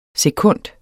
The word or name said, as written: SEKUNDS